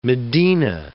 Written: medina

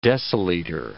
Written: deciliter

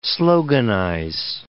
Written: sloganize

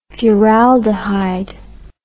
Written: furaldehyde

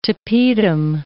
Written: tapetum

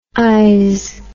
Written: ise

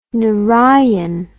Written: narayan

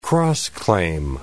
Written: cross-claim

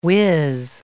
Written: whiz

